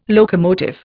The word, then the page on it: locomotive: Indian